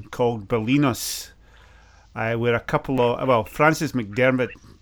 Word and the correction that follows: McDormand: you know the movie star? She lives there with her husband. – Yeah, you mean Frances McDormand? – McDormand, that's it.